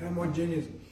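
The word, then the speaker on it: Hermogenes: This thou knowest, that all they which are in Asia be turned away from me, are whom Phygelius and Hermogenius, that's how I pronounce it, Hermogenius, and they are the ringleaders among these churches that are drifting away from what Paul had taught him.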